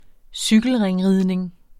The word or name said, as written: cykelringridning